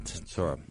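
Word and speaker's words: Metzora: you pronounce it, Metzorah? – Right. – Metzorah.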